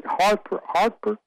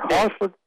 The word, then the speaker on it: Hertford: There's a Lord, and they pronounce it in England, they pronounce it Hartford, Lord Hartford, H-E-R-T-F-O-R-D. There's a county in North Carolina named for Lord Hertford.